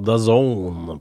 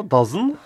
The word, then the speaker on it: The Zone: ja, det sånn du talte altså, Dazen. – Dazen, ja. Som da, ja. Det tok lang tid før jeg skjønte at det skulle da uttales The Zone. – Ja, Zone, ja, som I zonen, liksom.